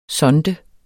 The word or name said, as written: sonde